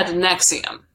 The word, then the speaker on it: NXIVM: It's actually spelled like NXIVM, but it's said NXIVM.